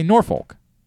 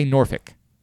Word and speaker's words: Norfolk: I always say Norfolk. Much like the word folks. – Yeah. – They say Norfolk. – Norfolk. – Norfolk. – Norfolk. – That's how they say it.